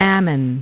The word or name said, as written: Ammon